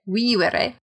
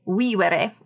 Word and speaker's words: vivere: So in Latin, the B in amabat, A-M-A-B-A-T, is pronounced as B, and the V's in vivere are pronounced as W. So there's two V's in vivere, and they both sound like W.